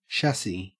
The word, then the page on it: chassis: Australian English